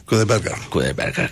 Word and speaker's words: Kudaibergen: Kudaibergen,